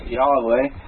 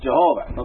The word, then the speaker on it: Jehovah: Now that's the word Yahweh, or because the Germans got a hold of stuff first and spelled it Jehovah, which they kind of pronounced Yahweh. We got it into English as Jehovah, okay?